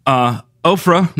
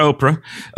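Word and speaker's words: Orpah: Ophrah, not Oprah, is stiff-necked.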